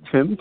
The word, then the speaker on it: Tim: So my name is Tim, actually. Tim, T-I-M.